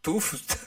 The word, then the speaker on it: Tufts: Tufts. – ¿Cómo? – A ver, T-U-F-T-S. – Tufts. Ah, Tufts.